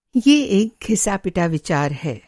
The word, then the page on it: घिसा-पिटा: घिसा-पिटा - گھسا پٹا